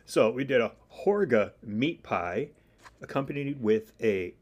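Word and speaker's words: Hårga: Is it Helga? – Oh, the Harga? The village? – Yeah. – The Horga. – Horga, yeah. – Horga? – Horga. That A with the ring over it, that actually makes it like an O sound.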